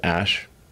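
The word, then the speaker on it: Æ: Um, and then the AE is like pronounced ash.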